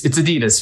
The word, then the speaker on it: Adidas: That is actually how you say it, but it bothers me when people say that. It's Adidas here in America.